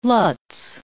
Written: lutz

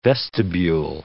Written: vestibule

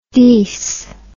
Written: deece

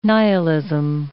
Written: nihilism

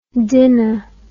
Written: dinna

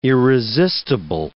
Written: irresistible

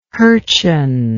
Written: hurcheon

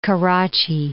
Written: Karachi

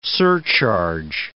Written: surcharge

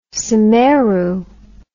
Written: semeru

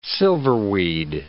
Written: silverweed

